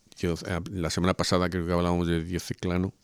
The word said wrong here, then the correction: Diocleciano: no sé si lo he pronunciado bien. Diocleciano. Diocleciano.